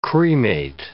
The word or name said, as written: cremate